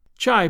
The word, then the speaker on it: Chai: Chai, pronounced shy, by the way, is the argot for Chicago.